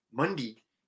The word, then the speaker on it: Munday: It's the Irish way to pronounce it. Munday. – And also, the South, right? – The South. Yeah. That's the way they say Munday.